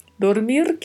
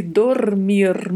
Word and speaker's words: dormir: Dormir, que é um verbo de terceira conjugação, pronuncia-se dormir. Muita atenção com o vibrato dos dois R's e a letra O sempre mais fechada.